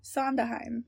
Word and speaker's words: Sondheim: Sondheim, yeah. – That's wrong. – It sounds wrong. I don't know how to say it. Sondheim. The D is silent.